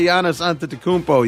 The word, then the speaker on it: Antetokounmpo: My guy Giannis Antetokounmpo, you just love when I say his name correctly.